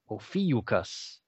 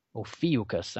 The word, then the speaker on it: Ophiuchus: Ophiuchus. That's how you pronounce that. Ophiuchus. I know there's a lot of people that are hacking the heck out of that. Ophiuchus. That's how that's pronounced.